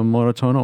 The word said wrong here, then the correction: Moretones: oder habe ich das nicht richtig gesagt? – Also Moretones ist der spanische Titel des Filmes und der heißt auf Deutsch blaue Flecken oder Hämatome oder sowas.